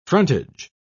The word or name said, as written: frontage